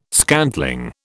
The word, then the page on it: scantling: British English